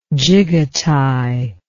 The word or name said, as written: dziggetai